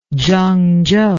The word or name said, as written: zhangzhou